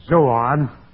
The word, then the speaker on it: zoa: These four living creatures, the Zoan, or Zoah plural and Zoan is singular. I believe I misled you a minute ago. Z-O-A is plural. Z-O-O-N is singular. Long O and then short O.